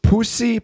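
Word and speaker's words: puszi: Kisses from Hungary, and then she says it's Pussy, pussy. That's how it's pronounced in Hungary.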